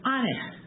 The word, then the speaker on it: Anee: And that term is Aneh. It's spelled A-N-E-E, but the double E's are pronounced like an F, okay? But once again, it's a two-syllable word, pretty easy to absorb.